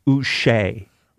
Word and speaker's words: Uche: Now, we've been saying it wrong. I looked up the pronunciation. It is Josh Uche. – Uche? – It's like Uche. It's Uche.